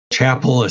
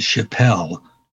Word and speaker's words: Chapel: You know, Chapel is C-H, and that's a hard C. And you got a lot of people who want to call it Chapelle, you know, where they want to put the French accent to it.